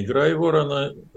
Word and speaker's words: Грайворон: Я думаю, что эти оценки вполне реалистичны, если предположить, что информация о том, что российские войска концентрируются где-то в районе Грайворона, так, по-моему, правильно будет ударение поставлено, это небольшой городок недалеко от границ с Украиной в Белгородской области, и ну, вблизи этого городка.